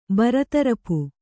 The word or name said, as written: ಬರತರಪು